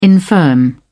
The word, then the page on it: infirm: British English